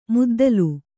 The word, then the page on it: ಮದ್ದಲೆ: maddale